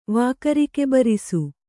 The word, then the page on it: ವಾಕರಿಕೆಬರಿಸು: Kannada